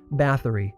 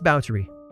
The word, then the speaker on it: Báthory: Just a note, if you've ever read about Elizabeth, you might have assumed that her surname, spelled B-A-T-H-O-R-Y, is pronounced Báthory. But you'd be wrong. It's Báthory. Trust me.